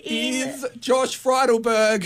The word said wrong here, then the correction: Friedenberg: Friedenberg. You've got to get his name right.